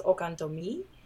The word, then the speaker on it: Okantomi: Okantomi, and if I'm pronouncing that wrong, please forgive me. – It's okay. It doesn't have the accent at the end, but it is pronounced Okantomi, which means Oshun's heart.